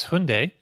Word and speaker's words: Hyundai: So the other bit of big news this week is Hyundai.